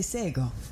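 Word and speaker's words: Shé:kon: When I first opened the show, you heard me say Sego. That is a Gonyageha word for hello, but it's not spelled like it sounds. It's spelled S-H-E colon K-O-N. The letter K sounds like a G.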